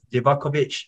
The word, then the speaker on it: Livaković: So we have their keeper, Dominik Livković from a team from, I'm not quite sure, Dinamo Zagreb, sorry. So Dominik Livaković, I'm sorry for the pronunciation.